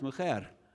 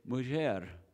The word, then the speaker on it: mujer: So, for example, the word for woman in Spanish today is pronounced mujer. The word in Ladino is mujer, which shows us that jota actually was pronounced as a J at one time.